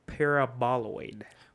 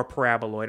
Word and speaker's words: paraboloid: What brand of fried crisp is in the shape of a hyperbolic paraboloid? – What? – Or paraboloid. I'm sorry. I don't know why I just mispronounced that. Paraboloid.